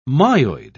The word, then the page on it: myoid: British English